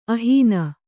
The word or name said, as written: ಅಹ್ನ